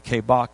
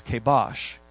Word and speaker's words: kabac: It's K-A-B-A-C. Sounds like kabak, but it's kabash. K-A-B-A-C.